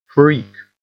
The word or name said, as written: freak